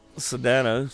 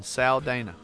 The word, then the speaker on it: Saldana: It's Saldano. Saldano. – Zoe Saldana. – I'm sure I'm not pronouncing that right. – Saldana. – S-A-L-D-A-N. Saldana.